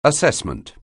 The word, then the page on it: assessment: anglais